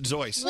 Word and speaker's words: Seuss: It's pronounced Zeuss. – Well, his name was Theodore Geisel, right? – Yeah. – So the Z sound makes sense.